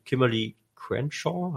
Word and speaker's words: Kimberly Crenshaw: Und der Begriff stammt aus dem bürgerlichen Recht, also aus dem amerikanischen Recht, und zwar von der Juristin Kimberly Crenshaw.